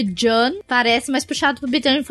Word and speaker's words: Jeanne: e é John mesmo. É John. É assim é a pronúncia do nome dela.